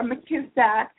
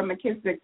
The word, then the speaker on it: McKissack: I am on Facebook, and the name is Cassandra McKissack. Last name, if I can spell that, capital M, little c, capital K, I-S-S-A-C-K, Cassandra McKissack, on Facebook.